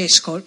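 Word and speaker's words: Eshkol: Io non so se si dice Eskol, perché lo pronuncio sempre, l'ho detto giusto.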